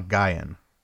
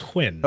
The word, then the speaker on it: Nguyen: I think it's pronounced Nguyen. – Okay. All right. You could be right. – It's a weird spelling, but I think if I remember correctly, it's pronounced Nguyen. – Okay. – At least that's what I've seen elsewhere.